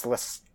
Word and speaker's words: Lestat: I think it's Lestat.